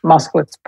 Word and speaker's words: Moskowitz: And I want to get the pronunciation of your last name, Moskowitz. – Moskowitz, perfect.